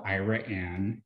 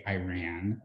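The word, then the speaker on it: Iraan: It's called Ira Ann. Yes, you pronounce it like that. Otherwise, people think you say Iran, like the Middle East, which a lot of people say that and then automatically think that I'm from there, which is not true.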